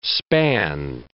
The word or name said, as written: span